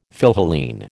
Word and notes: Philhellene: American English

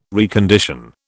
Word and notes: recondition: British English

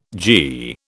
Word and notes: gie: British English

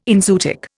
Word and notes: enzootic: Indian

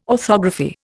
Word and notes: orthography: Indian

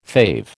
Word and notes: fave: American English

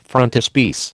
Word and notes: frontispiece: American English

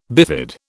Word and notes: bifid: British English

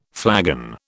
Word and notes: flagon: British English

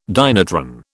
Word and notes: dynatron: British English

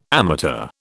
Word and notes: amateur: British English

